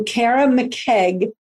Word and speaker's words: Kara McKeage: Today, I welcome Kara McKeage.